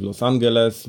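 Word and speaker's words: Los Ángeles: E, oczywiście rajd, który ma bardzo ciekawe mm, ceremonie startu i mety, zarówno w Concepcion, jak i w Los Angeles, bo tak to się wymawia po, po, po ich niemu.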